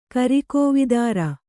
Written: ಕರಿಕೋವಿದಾರ

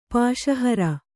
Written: ಪಾಶಹರ

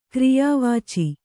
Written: ಕ್ರಿಯಾವಾಚಿ

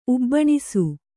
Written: ಉಬ್ಬಣಿಸು

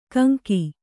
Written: ಕಂಕಿ

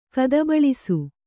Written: ಕದಬಳಿಸು

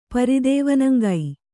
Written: ಪರಿದೇವನಂಗೈ

